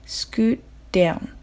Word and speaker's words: scoot down: a bit となるところです言いづらければ単語ごとに区切って発音しても全く問題ありませんあとはおまけですが scoot down の場合は scoot の t がストップ t になるのでちゃんと t の音として発音されませんなので scoot down scoot down となります Alright, repeat after me Could you scoot up a bit?